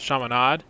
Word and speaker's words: Chaminade: First of all, apparently it's pronounced Chaminade, at least the way Dave Pasch said it last night on the broadcast, reunited with Bill Walton, ESPN.